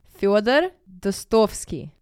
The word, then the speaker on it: Fyodor Dostoevsky: Fyodor Dostoevsky.